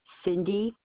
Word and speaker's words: Cindy: Cindy, C-I-N-D-Y. Deshaw is my last name. It's spelled like Des Champs. It's D-E-S, capital C-H-A-M-P-S.